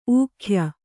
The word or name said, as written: ಊಗಾಯ